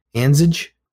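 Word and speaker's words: Andrzej: He's a senior game designer, and his name is Anzige. And it's A-N-D-R-Z-E-J. Anzige? I think the R is silent.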